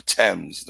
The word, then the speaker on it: Thames: They call that river the Thames, don't they? – Is right. How pronounced that? Right.